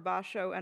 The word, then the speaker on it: Basho: Am I saying it right? You say Basho? – Yes, Basho.